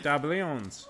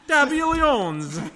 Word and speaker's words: Diabolians: The Diabolians. – So I said it right, Diabolians. – Yeah. – Diabolians.